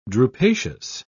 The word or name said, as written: drupaceous